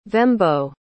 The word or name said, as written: vembo